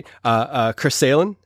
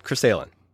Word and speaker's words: Chris Sahlin: I have a great guest with me today, Chris Sahlin. Say hey. – Hey. – Am I saying it right? Chris Sahlin.